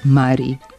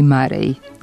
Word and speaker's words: Murray: Teniski turnir igra se u Šangaju, a Đokovićev protivnik zove se Andy Murray. Sportski komentatori su ga prvo prozvali Murray, ali to se ime nije toliko ustalilo u govoru da ne bi mogao da bude prihvaćen pravilan izgovor Murray.